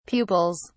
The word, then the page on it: pupils: British English